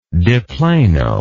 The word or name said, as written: de plano